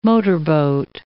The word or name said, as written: motorboat